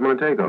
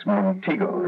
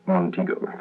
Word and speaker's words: Montego: I thought it was Montego. – No. It's Montego, Mercury's newest, lowest-priced luxury car with a fine car touch. It combines cougar excitement with full six-passenger comfort. – Thank you. – Pronounced Montego.